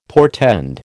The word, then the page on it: portend: American English